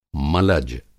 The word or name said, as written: मलज